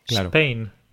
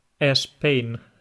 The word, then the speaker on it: Spain: Starbucks, porque, como ya sabes, en España no, no, no, sabemos o no pronunciamos solo la S, siempre añadimos una E al principio. No decimos Spain, decimos Spain.